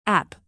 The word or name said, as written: app